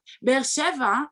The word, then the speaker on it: Be'er Sheva: So like Be'er Sheva. – Be'er Sheva. Okay. Yeah. – Okay. Good sorry, question. I'm saying it wrong. – No. Yeah, very good.